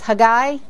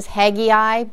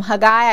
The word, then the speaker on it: Haggai: All right, because we're not all that familiar with him, I'm going to pronounce his name the Jewish way, which is Haggai. Usually you see it as Haggai, but we're going to go ahead and call him Haggai.